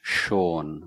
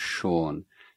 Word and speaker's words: Sean: So my name is Sean. My original name is Sean. And he couldn't pronounce this sh sound.